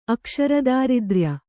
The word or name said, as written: ಅಕ್ಷರದಾರಿದ್ರ್ಯ